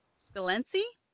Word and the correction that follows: Scalzi: Scalance. We just said it right earlier, Scalzi. but whatever. – Scalzi. Scalzi, John Scalzi.